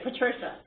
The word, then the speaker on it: Patricia: I think I pronounced Patricia a little bit in a Latin format because I'm constantly hanging out with all these people from Europe and Latin America and so forth. But Patricia is American, so I think you use the pronunciation Patricia, right? – That's right, Patricia.